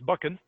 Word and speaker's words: Buchan: and I've already mispronounced it. It's Buchan. Actually, I have a friend whose last name rhymes with it, and that's how I was trying to remember it.